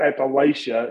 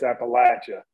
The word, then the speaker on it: Appalachia: I mean, it's not Appalachia, it's Appalachia. I hear that all the time up here, and that wears me out. So I make a lot of on-spot corrections about Appalachia and Appalachia.